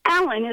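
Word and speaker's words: Allan: With one exception, Allen is spelled A-L-L-A-N. I constantly had to spell it for people since it's not the common spelling.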